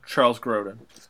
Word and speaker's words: Charles Grodin: Charles Grodin.